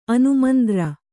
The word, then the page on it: ಅನುಮಂದ್ರ: kannada